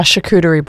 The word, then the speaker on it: charcuterie: A charcuterie board.